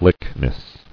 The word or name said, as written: Lychnis